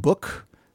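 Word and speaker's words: Bouc: And he's talking to his friend Book, who B-O-U-C. I looked up the pronunciation before. – Book.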